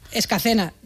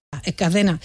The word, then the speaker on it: Escacena: Nacida y crecida y vivida en Escalena del Campo, en Huelva. Escacena. Mira que te lo he dicho mal varias veces, ¿eh? Por esta. Escacena.